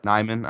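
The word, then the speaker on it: Nijman: Nyman. I want to make sure I'm not putting that J in there on accident.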